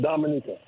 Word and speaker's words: Dominica: The natives, they pronounce it Dominica.